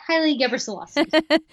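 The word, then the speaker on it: Gebrselassie: Haley Gabrielassie. Is that how you say it? – Yeah, Haley Gabrielassie.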